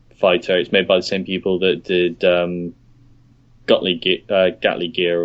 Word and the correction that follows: Guilty Gear: or whatever it's pronounced. – Is it Guilty Gear?